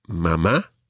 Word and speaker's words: mamá: But just so that you know, children are more likely to say mamá. – Mamá. – And papá. – Papá. – Now, just one other wee thing, we mentioned accents already. Mama and papa both have accents on the last syllable.